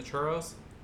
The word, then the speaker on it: churro: That'll be up too, referencing the churros. Heron, give you a little education on that one too. – Say it right. – Hey, churro. – There you go.